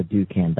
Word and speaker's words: Dukan: and it's called the Dukan diet.